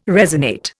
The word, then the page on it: resonate: Indian